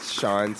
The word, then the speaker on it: Shaun: You can catch me on Instagram at Sean Thomas Gibson. That's S-H-A-U-N.